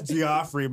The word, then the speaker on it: Geoffrey: That's Geoffrey, man. Geoffrey starts with a J.